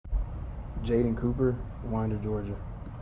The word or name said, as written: Jadaan Cooper